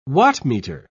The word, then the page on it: wattmeter: British English